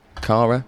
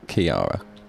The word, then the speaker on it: Ciara: Kara? It's not Kara, it's like Kiara. What is it? There you are. C-I-A-R-A. How do you spell that? Storm Kiara?